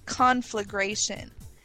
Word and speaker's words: conflagration: But the way you pronounce it is actually conflagration. And okay, you might want to think about confirmation with that emphasis on the first syllable to kind of mirror that out as conflagration. – So we're looking at conflagration here. There's no conflagration or configuration. It's just conflagration.